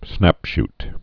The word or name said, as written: snapshoot